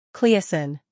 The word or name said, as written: Cleocin